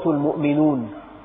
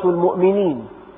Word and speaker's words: المؤمنون: لك أن تقول سورة المؤمنون، وبالإعراب سورة المؤمنين، سورة المؤمنين، لكن لك أن تقول المؤمنون اسم للسورة، لك أن تلفظها بالرفع دائما، تقول سورة المؤمنون. العلماء يقولون على الحكاية، أي تحكى هكذا فلا نخضعها للإعراب، فلك أن تقول سورة المؤمنون وسورة المؤمنين، وكلاهما صحيح. في بعض التفاسير شرحت كلمة رب العالمين بما يشعر بأنها تعني الثناء المطلق على الله، الثناء المطلق.